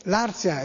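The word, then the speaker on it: Larth: Ma a Viterbo, zona etrusca per eccellenza dell'Alto Lazio, che ha una continuità etrusca con la Toscana, ovviamente Larzia è un bel nome etrusco, e c'è il nome maschile che è Larz. Si scrive l'ARTH, anche Larzia si dovrebbe scrivere senza Z ma con TH, Larzia.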